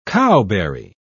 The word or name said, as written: cowberry